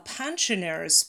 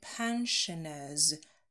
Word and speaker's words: pensioners: And pensioners, произносим «ш», звук у нас со в середине pensioners, pensioners.